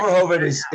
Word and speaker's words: Verhoeven: Paul Verhoeven. You have to say it. – Verhoeven. – The original RoboCop was directed by Paul Verhoeven. It was his eighth. Am I saying it right? – Yeah, that's perfect.